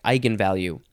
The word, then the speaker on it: eigenvalue: I'm going to say it's pronounced eigenvalue.